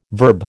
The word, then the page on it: verb: American English